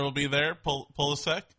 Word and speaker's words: Pulisic: Pulisic. Pulisic.